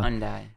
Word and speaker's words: Hyundai: Because we have a little eco-friendly Hyundai car. I don't know how to pronounce it quite well. – Hyundai.